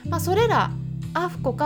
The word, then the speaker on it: AAFCO: それでですねペットフードの場合ワンちゃん用でも猫ちゃん用でもどちらでもですねアメリカの場合はアフコといって AAFCO と書いて